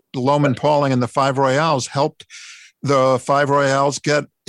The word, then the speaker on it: Royales: I said, it sounds like a baseball team to me, the Five Royals. But we never heard that before. Our disc jockey in Memphis called them the Five Royales. Mm-hmm. It's spelled the same, but it's bigger. Royales.